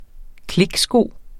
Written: kliksko

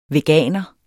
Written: veganer

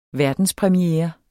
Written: verdenspremiere

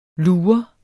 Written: lur

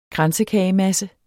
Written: kransekagemasse

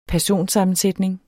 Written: personsammensætning

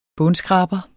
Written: bundskraber

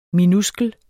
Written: minuskel

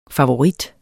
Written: favorit

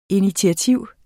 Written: INITIATIVERNES

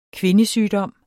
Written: kvindesygdom

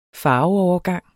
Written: farveovergang

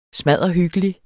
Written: smadderhyggelig

